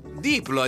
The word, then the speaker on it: Diplo: Diplo, hai detto bene.